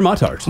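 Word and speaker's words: Muttart: Yeah, and I got the same way the, God bless him, the Mutart Conservatory came back and said, you're pronouncing it wrong. We're Mutart. – Oh, they're Mutart.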